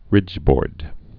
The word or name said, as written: ridgeboard's